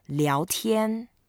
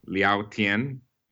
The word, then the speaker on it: 聊天: So when you put a board and chat with the sky together, then you pronounce as 聊天. – 聊天.